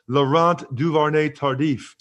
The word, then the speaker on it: Laurent Duvernay-Tardif: You know, the Chiefs now have issues at both guards, left and right, because of Laurent Duvernay-Tardif going ahead and opting out.